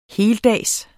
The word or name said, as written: heldags